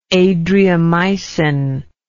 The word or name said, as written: Adriamycin